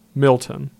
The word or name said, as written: Milton